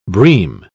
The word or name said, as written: bream